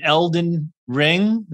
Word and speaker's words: Elden Ring: And you know, of course, the legendary DJ, Daniel, who is often twitching the new show, new game, Elden Ring.